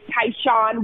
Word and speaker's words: Tyshun: My social media handles, even on LinkedIn, my personal one is Tyshawn Wartlaw, first name, T-Y-S-H-U-N.